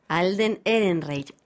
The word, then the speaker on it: Alden Ehrenreich: Vamos a enseñarles a pronunciar este nombre porque decir Harrison Ford es muy sencillo, pero cuando hablamos del nuevo Han Solo, el jovencito, su nombre es... – Alden Ehrenreich o Ehrenreich.